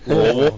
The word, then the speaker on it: Louisville: Louisville. That's how you say it.